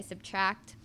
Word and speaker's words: SBTRKT: And it's by Subtract, but it's spelled S-B-T-R-K-T. – Just without the vowels.